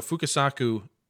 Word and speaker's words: Fukasaku: The thing that works for Battle Royale for me is, you know, Fukasaku, or wait, did I say that right? Yeah, I put the wrong name on here and it completely confused me. but anyways, Fukasaku